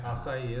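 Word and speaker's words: açaí: Acai is what I used to call it. – No, we've all said it wrong. – Yeah. – It's okay. Acai is how you say it.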